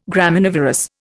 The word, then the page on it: Graminivorous: Indian